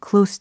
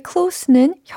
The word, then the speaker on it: close: Close to You. 그죠? 거기서의 close는 형용사 close 였어요. 그래서 발음이 close 였다라는 거 알려드렸잖아요. 어, 지금은 동사로서 close 그리고 it remains closed 끝소리까지 챙겨가시면 되겠습니다.